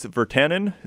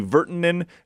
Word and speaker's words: Virtanen: And then Santeri, I thought it was Virtanen, similar to Jake Virtanen. It's actually Virtanen is how they're pronouncing it.